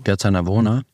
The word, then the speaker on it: Piazza Navona: Habe ich das richtig gesagt? Navona? – Piazza Navona, ja.